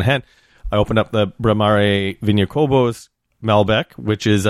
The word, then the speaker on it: Bramare: The exact word is Bramare. It sounds like that in Spanish, Uco Valle Malbec.